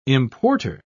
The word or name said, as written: importer